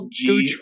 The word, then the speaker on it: Gooch: Can you spell Gooch for everyone? – G-O-O-C-H. – Thank you. There's no T. There's no silent T.